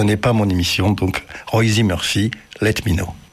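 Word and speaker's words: Róisín: Alors, elle est irlandaise, il faut prononcer Roisin.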